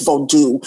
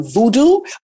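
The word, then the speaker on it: Vodou: is voodoo. People would typically pronounce the term voodoo. A lot of practitioners of this tradition prefer the pronunciation voodoo to separate it from the way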